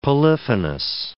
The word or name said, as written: polyphonous